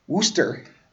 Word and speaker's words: Worcester: Hailing from Worcester, Worcester. Well, if you're going to pronounce it like you're from there, it's Worcester.